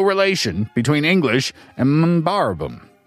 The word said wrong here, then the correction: Mbabaram: That's hard to pronounce. – Mbabaram. – Mbabaram.